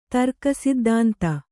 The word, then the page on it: ದೃಕ್ಸಿದ್ಧಾಂತ: kannada